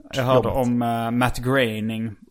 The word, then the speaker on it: Groening: Så då läste jag att det ska uttalas som färgen grå. Groening.